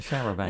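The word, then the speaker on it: charabanc: Was it a charabang? – Yeah, that was the one. – Yes, a charabang.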